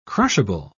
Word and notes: crushable: British English

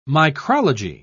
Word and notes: micrology: British English

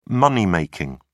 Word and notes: money making: British English

